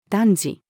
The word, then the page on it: 男児: female